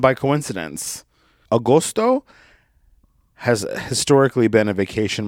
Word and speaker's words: agosto: And in Italian, it's pronounced agosto. Agosto. Agosto. Agosto. It's spelled A-G-O-S-T-O. Agosto. Agosto. A-G-O-S-T-O.